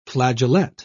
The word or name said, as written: flageolet